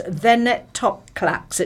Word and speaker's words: venetoclax: And now the cancer drug, and this is, let me make sure I say this correctly, it's venetoclax. It's VENETOCLAX. That's what it's called.